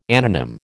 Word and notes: anonym: American English

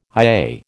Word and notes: IA: American English

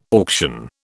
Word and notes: auction: British English

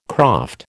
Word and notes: croft: American English

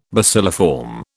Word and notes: bacilliform: British English

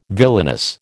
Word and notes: villainous: American English

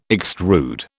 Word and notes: extrude: British English

